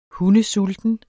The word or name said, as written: hundesulten